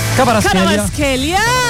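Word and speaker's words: Kvaratskhelia: Cavicha Cavaraschelia, ¿cómo se dice eso? ¿A usted le gusta? – Cavaraschelia.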